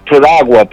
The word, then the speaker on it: Piragua: Is it Paraguay? – It's close to Piragua.